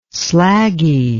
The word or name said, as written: slaggy